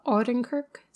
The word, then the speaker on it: Odenkirk: One thing I can recommend, though, that does have, I don't know how to say his name, but Bob Odenkirk.